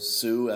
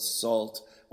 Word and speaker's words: Sault: Before that, we heard a track from the band, or collective, better word, Sault, S-A-U-L-T. These parts here in Montreal, we pronounce that Sault. Others might call it salt.